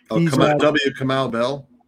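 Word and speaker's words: Kamau: I'm mispronouncing his first name. Camille Bell. – Kamal Bell. – Yeah, that's him.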